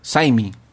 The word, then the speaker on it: Syme: Pero antes, eh, él tendría que pasar por Escocia y quedarse un mes con su gran amigo James Smy. Se escribe S-Y-M-E. Smy. Smy. Sime. No sé cómo se pronuncia. – Saimi. James Saimi este periodo de un mes que,